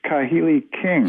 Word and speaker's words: Kahili King: You have met Serge Kahilking. I don't know if I'm pronouncing his name right. – Serge Kahili King. – Yes.